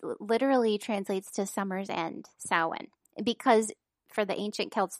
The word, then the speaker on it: Samhain: I get so confused by that word, the way it's spelled. – Samhain. – I can never remember because those letters don't line up.